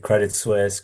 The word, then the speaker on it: Credit Suisse: Did I pronounce the New York name correctly? – You did it correctly. Credit Suisse, Credit Suisse.